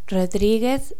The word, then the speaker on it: Rodriguez: Patricia is P-A-T-R-I-C-I-A, Patricia. Let me repeat. P-A-T-R-I-C-I-A, Patricia. My family names are Rodriguez Sarmiento. The first one is Rodriguez. R-O-D-R-I-G-U-E-Z, or Z. R-O-D-R-I-G-U-E-Z, Rodriguez. Let me say it again. R-O-D-R-I-G-U-E-Z, or Z. Rodriguez. There's an accent on the I too. Rodriguez. Then Sarmiento is S-A-R-M-I-E-N-T-O. Sarmiento. Again, S-A-R-M-I-E N-T-O.